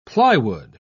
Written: plywood

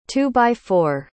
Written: two-by-four

